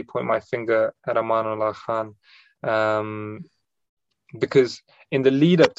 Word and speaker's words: Amanullah Khan: How did Amula Khan come, sorry if I said his name wrong, but how did he come to power? – Amanullah Khan, how did he come to power?